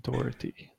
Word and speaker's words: Dougherty: His name is Dougherty. That's how you pronounce it.